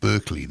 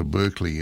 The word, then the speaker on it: Berkeley: The first one is called The Devil Went Down to Berkeley, that's how you say it. Devil Went Down to Berkeley, and Alison Brown on banjo, Daryl Anger on fiddle, Mike Marshall on mandolin, Tony Rice on guitar, Todd Phillips on bass.